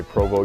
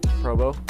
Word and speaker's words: Provo: Provost? – I don't know how to say it. Provo? – I would have to say Provo, Utah. – It's Provo, Utah. – Provo? – Provo.